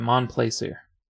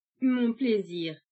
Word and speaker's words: Mon Plaisir: Old Battle Theme 2 by Mon Plaisir. It's French. How do you say this? – Mon plaisir. – Oh.